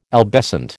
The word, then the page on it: albescent: American English